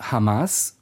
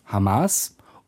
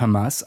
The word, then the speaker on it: Hamas: Und vielleicht noch ein Beispiel, die Bundesinnenministerin Nancy Faeser hat das arabische Akronym Hamas vor kurzem nicht Deutsch Hamas oder mit dem arabischen Kehllaut Hamas ausgesprochen, sondern sie sagte Hamas. Das ist hebräisch.